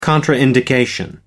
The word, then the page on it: contraindication: American English